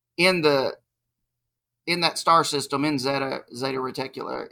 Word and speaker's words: Zeta Reticuli: And it is a very detailed story about an alien exchange program with humans in the star system Zeta Reticula. Did I say that right? – Or is that yeah, right? That's it.